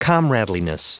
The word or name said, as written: comradeliness